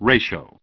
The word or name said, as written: Ratio